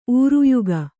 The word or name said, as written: ಊರುಯುಗ